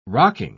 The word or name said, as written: rocking